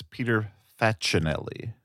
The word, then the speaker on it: Facinelli: As long as we're correcting name pronunciations, I believe it's Peter Facinelli.